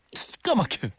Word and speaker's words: 기가 막혀: 기는 거의 묵음처럼 나와야 돼요. 알죠? 기가 막혀야만 안 되고 기가 막혀.